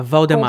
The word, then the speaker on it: Voldemort: Voldemort se pronuncia sem o T no final. Que uhum. – isso uhum. em, na pronúncia francesa é um clássico. Uhum. É, então, Voldemort, é Voldemort.